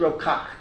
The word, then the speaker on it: Kach: In Israel, when I would say Rav Kayach, I would be corrected Rav Kach. That's the way they pronounce it here. It's like Gur and Ger.